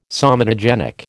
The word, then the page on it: somatogenic: American English